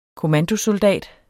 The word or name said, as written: kommandosoldat